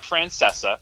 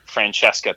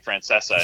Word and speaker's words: Francesa: But I was just with someone two weeks ago who was calling him Francesca too, and I'm like, is there some like deep like uh, underground Italian pronunciation that like that I don't know about? But he calls himself Mike Francesa.